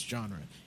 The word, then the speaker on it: Janra: But sometimes I just meet people, and I'm like, hey, my name's Genre. And they're like, how do you spell that? In like, J-A-N-R-A.